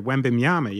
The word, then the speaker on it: Wembanyama: The really tall gentleman, Wembanyama. Is that how you say it? – Oh, Victor Wembanyama. Yeah.